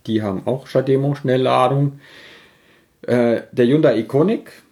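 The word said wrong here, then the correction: Ioniq: oder Hyundai, wie das heißt, Ionic.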